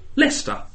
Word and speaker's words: Leicester: Leicester. – Leicestershire. – Not Leicester. – Leicester. – Just Leicester.